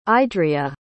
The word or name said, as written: idriya